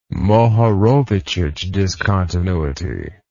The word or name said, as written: Mohorovicic discontinuity